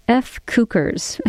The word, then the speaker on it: FCUKERS: Again, it's Mexican Institute of Sound, Meridian Brothers, and the band named F. Cukers. It's spelled F-C-U-K-E-R-S. Again, some folks call it F.